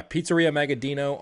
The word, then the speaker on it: Pizzeria Magaddino: You guys, if you're listening to this and you want to go check it out, it's Pizzeria Magaddino. Is that how you say it? – You got it. – All right. Yeah, Pizzeria Magaddino on Instagram, and there's a picture of your van, and it has looks like two Gosney ovens.